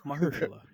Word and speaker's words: Mahershala: Mahershala. – Mahershala? There you go. – Yeah. Just remember Methuselah from Simpsons. Put a Hirsch on it. – Oh, that's good, Mahershala.